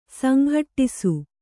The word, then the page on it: ಸಂಘಟ್ಟಿಸು: sanghaṭṭisu